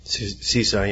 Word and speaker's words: CISA: CISA, yeah.